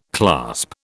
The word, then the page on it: clasp: British English